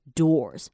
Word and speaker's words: doors: 其实 d 就是 d d d，其实重点是在后面的 o o r， or。好，所以它一开始会有很短的 u 音，然后后面就变成 or，所以就变成 or。 doors.